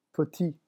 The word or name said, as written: petit